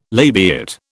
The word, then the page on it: labiate: British English